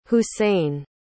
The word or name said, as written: hussein